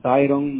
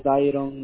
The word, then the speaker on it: Dayron: You have a pleasure with Tyrone Nolasco. – I'm sorry. I just heard Mr. Nolasco. Is that right? – Yeah. Tyrone Nolasco. – Tyrone? Can you spell it for Tyrone. – Me? – D-A-Y-R-O-N.